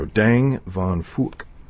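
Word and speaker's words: Dang Van Phuc: Dang Van Fuck. That's how he pronounced his name.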